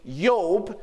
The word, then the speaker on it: Job: The name Job, which they don't have J's in Hebrew, it would be pronounced as a Y. So they would say Yob.